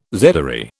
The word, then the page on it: zedoary: British English